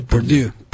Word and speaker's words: Purdue: Purdue.